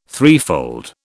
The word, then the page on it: threefold: British English